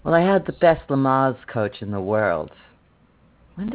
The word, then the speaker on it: Lamaze: How do you pronounce? Is it Lamaze? – Lamaze. – Lamaze. Good to know.